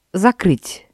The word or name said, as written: закрыть